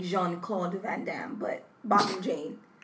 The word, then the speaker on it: Botham Jean: Botham Jean.